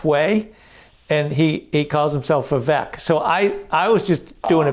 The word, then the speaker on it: Vivek: It's pronounced Vivek.